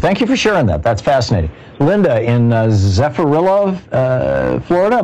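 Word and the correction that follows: Zephyrhills: Am I saying that right? – No, Zephyr Hills, Florida. – Oh, Zephyr Hills. Two words.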